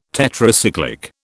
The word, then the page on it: Tetracyclic: British English